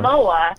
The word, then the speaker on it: Momoa: was, jason momoa yeah jason, jason momoa.